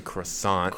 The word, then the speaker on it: croissant: Best bread in the world is a croissant. – Croissant. It's pronounced croissant. – No. That's crescent.